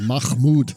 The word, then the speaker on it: Mahmood: It's Mahmood. Come on, get it right.